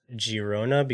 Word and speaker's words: Girona: It is Girona because it's in Catalonia, and in the Catalan language, G's are pronounced with like the soft G.